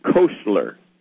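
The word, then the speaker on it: Koestler: And you can get The 13th Tribe by Arthur Kessler, and his name is not spelled like it sounds. It'd be more like Kostler. It's K-O-E-S, like in Sierra, T-L-E-R. K-O-E-S, like in Sierra, T-L-E-R, Arthur.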